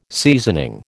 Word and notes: seasoning: American English

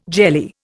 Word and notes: jelly: Indian

